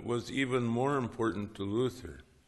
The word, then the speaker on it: Luther: His name in German was Luder, L U T T E R or L U D D E R. Um, he pronounced it L U T H E R, or Luther, punning on the word for freedom in Greek, Eleutherius.